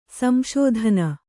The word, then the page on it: ಸಂಶೋಧನ: sm'śodhana